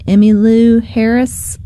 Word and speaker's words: Emmylou Harris: Only Emmylou Harris left.